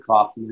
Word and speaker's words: Kaufman: But again, my name is Brad Kaufman, and that's spelled K-A-U-F-M-A-N.